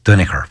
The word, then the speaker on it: Duinnachair: it was spelled D-U-I-N-N-A-C-H-A-I-R, which is Dunaher, which is Danaher.